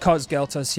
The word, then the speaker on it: Cos Galtos: We've got Cos Galtos here today. Did I say it right? – Yeah, of course you did.